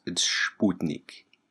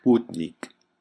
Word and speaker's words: Sputnik: I guess technically, you know, for the science, scienceophiles, it's Sputnik. So, I just wanted to get that out there. I don't want people to write us and say, hey, you didn't pronounce it correctly, because I know. Sputnik.